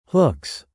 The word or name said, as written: hooks